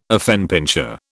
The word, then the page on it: affenpinscher: British English